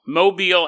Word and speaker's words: Mobile: We're headed to Mobile, Alabama. I don't know why they pronounce it like that, but they do. Mobile, Alabama.